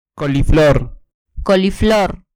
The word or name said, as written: coliflor